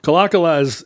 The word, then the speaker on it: Kalakala: Kalakala is correct, right? – Kalakala is the accepted pronunciation, at least around here.